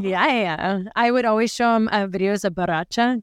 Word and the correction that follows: bachata: Did I say that right? – Bachata. – Oh, bachata. Bachata.